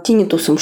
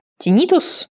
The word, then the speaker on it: тинитус: Отоневрология – это узкая подспециальность, которая занимается в первую очередь головокружением и э, тинитусом, шумом в ушах. Как вы помните из первого выпуска, тинитус, некоторые врачи говорят тинитус, но я буду ставить ударение на второй слог, как в словаре.